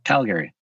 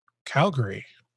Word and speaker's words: Calgary: Um, Did you say Calgary? – Calgary. – I've, I mean, us Americans, we always pronounced it Calgary.